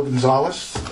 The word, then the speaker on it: Gonzales: Okay, my name is Joe Gonzalez, last name G-O-N-Z-A-L-E-S, first name J-O-E.